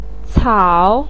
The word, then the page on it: cao3: pinyin